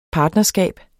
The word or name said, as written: partnerskab